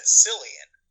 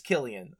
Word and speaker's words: Cillian: how you pronounce that dude's name? I always said Cillian. So did, I. So, so did I. I I think it's Killian. I I, I figured okay. out re- just yeah, from hearing other better. people pronounce it, but I always thought it was Cillian as well. Yeah. – Okay. – I think it's Killian though.